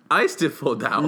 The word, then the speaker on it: Eisteddfod: Eisteddfodau.